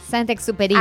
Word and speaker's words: Saint-Exupéry: Saint-Exupéry.